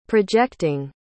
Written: projecting

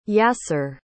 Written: Yaser

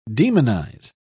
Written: demonize

